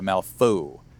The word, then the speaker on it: Malifaux: I went and got some, and I always say, how do you say the Malifaux? There you go, yeah, Malifaux. – Yeah. – Yeah, because that's right.